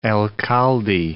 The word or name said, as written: alcalde